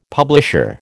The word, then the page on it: publisher: American English